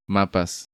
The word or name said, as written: Mapas